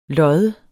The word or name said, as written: loddes